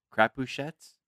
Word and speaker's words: Crapuchettes: It's spelled C R A P U C H E T T E S. Crapouche. Crapouchettes. Crapouchettes. – Crapouchettes.